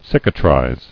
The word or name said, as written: Cicatrizing